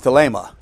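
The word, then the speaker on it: thelema (θέλημα): The first two are a noun and a verb, thelo and thelema, G2307 and 2309. And also G1012 and 1014, which is boule and boulamai if you want the Greek words. That's how they're pronounced.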